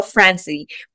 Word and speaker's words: Franze: It's technically Franze. I'm Italian, so there's supposed to be an accent on the E, but when my grandparents immigrated to Canada, they dropped it. So we just go by Franz now, but either one works.